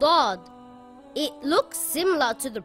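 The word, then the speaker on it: Dod (ضاد): Dod is also a heavy letter and should always be pronounced heavy. The closest letter would be the letter D and the letter Dal, but it is pronounced a lot heavier. The sound also comes from the back of the mouth, Dod.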